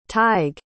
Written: tighe

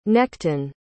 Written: nechten

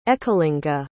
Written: Ekalinga

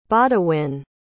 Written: bawdewyne

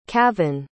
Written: cavan

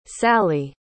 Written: salli

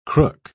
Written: crook

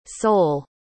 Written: Seoul

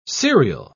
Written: serial

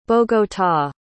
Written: bogota

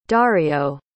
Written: dario

